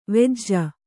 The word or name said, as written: ವೆಜ್ಜ